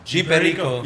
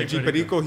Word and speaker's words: G Perico: Sam Perico. – G Perico. – Yeah, G Perico.